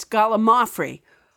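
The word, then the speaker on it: gallimaufry: It rolls off my tongue as gallimafree, but you're right. It's gallimafree.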